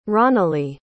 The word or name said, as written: ronalee